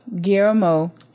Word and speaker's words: Guillermo: Oh, Guillermo. – Guillermo. – Guillermo. What is wrong with British people and the double L? It's not that difficult. Like right. Tortilla. – Guillermo it's del Toro tortilla.